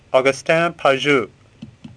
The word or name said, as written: Pajou